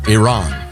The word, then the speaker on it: Iran: This is from Alan on the pronunciation of Iran. – Okay. – The I can be ire, as in irresponsible. Oh, ear. But R-A-N is ran. Not Ron, not Ren, not Rin.